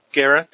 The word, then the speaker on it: Guerra: Pia Guerrera. I hope I've said her name right. – Guerra, there you go.